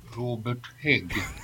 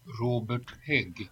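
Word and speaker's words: Hägg: Here's how you say it in the Swedish accent. – Robert Hag. – Yeah, play that one more time. – In case you missed that. – Robert Hag.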